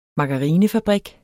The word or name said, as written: margarinefabrik